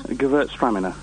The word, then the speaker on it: Gewürztraminer: It's called, I think it's Gewürztraminer. – Gewürztraminer. – Oh, is that Gewürztraminer? – Yes, and it's not always sweet.